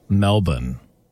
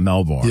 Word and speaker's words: Melbourne: that it's actually pronounced Melbourne and not Melbourne.